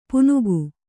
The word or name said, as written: ಪುನುಗು